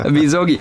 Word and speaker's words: Misogi: Oder wie wird es ausgesprochen im Japanischen? – Yamisogi. – Yamisogi, einfach. Kann man das aussprechen, oder? Misogi.